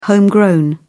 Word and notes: homegrown: British English

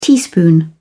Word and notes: teaspoon: British English

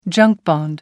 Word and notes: junk bond: American English

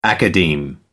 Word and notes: academe: American English

